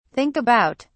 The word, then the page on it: think about: British English